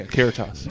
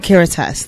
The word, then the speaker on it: Caritas: And then right after the big performance that we had, I actually went to, I believe it was Caritas? – Yeah, Caritas. – Caritas, thank you.